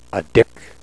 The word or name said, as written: adik